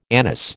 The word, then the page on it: anise: American English